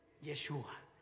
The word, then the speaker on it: Yeshua: En hebreo se pronuncia Yeshua, pero en español se dice Jesús. Y si estoy en Estados Unidos dirán Jesus.